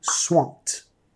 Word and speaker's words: swamped: Nota la ED en este caso, vamos a pronunciar como una T, swamped.